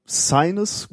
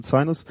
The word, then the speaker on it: Cygnus: Äh, hast du es das mitbekommen, dass dieser Versorgungsfrachter äh, Sinus, würde ich nicht aussprechen, äh, Cygnus geschrieben, Sinus, ähm, beim Start explodiert ist ähm, in Virginia.